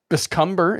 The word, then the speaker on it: biscumber: So biscumber is a verb. Biscumber is how you pronounce it.